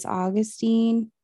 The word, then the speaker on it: Augsteen: Yeah, you posted something recently about kids like misspelling or mispronouncing your last name. Mm-hmm. I was like, is it Augustine? Is it Augustine? What is it? – That's what they ask me all the time.